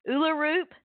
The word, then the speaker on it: Ullerup: And I have to say, I mispronounced her last name. I was saying Erlerup. The gentleman in the commercial said Uluroop. And her last name is spelled Ullerup.